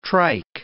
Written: trike

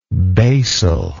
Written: basally

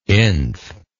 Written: inf

